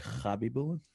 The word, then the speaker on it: Habibulin: H-A-B-I-B-U-L-I-N, right? – Actually, I think the correct pronunciation would actually be Hobby Boolin.